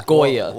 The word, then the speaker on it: Goya: It's Goya. – Well, whatever. Goya, dude.